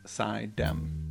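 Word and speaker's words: Suydam: I looked up on, because I wasn't sure how to pronounce the name because it's spelled Sudam, but according to pronunciation of upstate New York places and names, it's pronounced Sidham.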